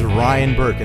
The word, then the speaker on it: Ryan Burke: Hey, this is Ryan Burke, and that is spelled R-Y-A-N-B-U-R-K-E.